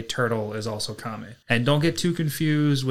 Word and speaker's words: kame: Today's Lessons is going to be the Japanese word for tortoise, and that is kami. That is spelled K-A-M-E, and that is very fitting for today because the first half of season 2, we follow the fight with the spirit tortoise.